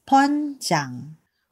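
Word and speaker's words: 번장: B-U-N-J-A-N-G. Bunjang. But in Korean, it is pronounced and spelled as Bunjang. – Usually, nagkakamali po yung pronunciation nila ng Bunjang kasi B-U-N yung spelling niya. Pero ang tunay na pronunciation po niya ay Bunjang.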